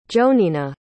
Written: jonina

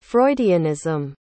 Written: freudianism

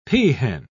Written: peahen

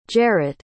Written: jarret

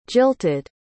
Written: jilted

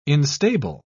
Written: instable

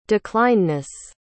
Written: declinedness

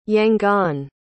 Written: yangon

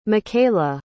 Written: mikayla

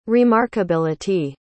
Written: remarkability

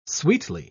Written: sweetly

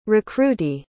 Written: recruitee

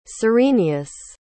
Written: cyrenius

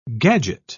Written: gadget

